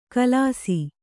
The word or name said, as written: ಖುಲಾಸು